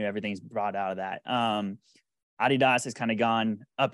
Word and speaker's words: Adidas: Adidas.